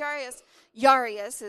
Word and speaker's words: Jairus: So this guy named Jairus is really how you would pronounce it.